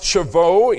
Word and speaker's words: Shavuot: Now tonight, we're going to deal with the Feast of Pentecost, or what the Jewish people call the Feast of Weeks, and it is pronounced Shavuot in the Jewish language.